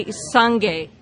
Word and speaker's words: sangye: This is the way it's pronounced, the second one. So say sangye.